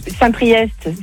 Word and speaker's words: Saint-Priest: Saint-Priest, c'est EST, tu le prononces.